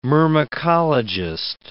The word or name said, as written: myrmecologist